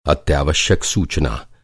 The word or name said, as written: अत्यावश्यक